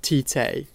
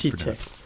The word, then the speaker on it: Tite: Sorry, you have to pronounce it the way Brazilians Tite.